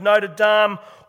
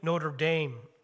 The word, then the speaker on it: Notre Dame: Rudy longed to play for the Fighting Irish, the college football team of Notre Dame, or as the Americans say, Notre Dame.